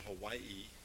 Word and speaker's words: Hawaii: When I was walking into the gymnasium for the first assembly in Hawaii, that's how you pronounce it. The W is like a V.